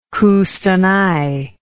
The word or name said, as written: Kustanai